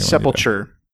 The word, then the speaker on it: sepulture: Sepulture. I'll spell it. S-E-P-U-L-T-U-R-E. Yeah, like a vulture.